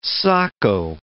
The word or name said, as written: socko